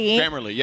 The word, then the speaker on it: Grammarly: Grammarly, yeah.